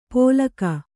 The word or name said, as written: ಪೋಲಕಾ